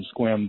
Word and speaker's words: Sequim: As a matter of fact, you took a call from Sequim before, and I was surprised that you pronounced it correctly, you know, but I'm sure you're familiar with that, the Washington area, evidently.